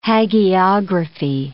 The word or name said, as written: hagiography